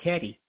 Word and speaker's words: Cadiz: It's called either Gades or, and it looks like Cadiz, but she goes, no, it's called Cadiz.